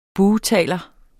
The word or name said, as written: bugtaler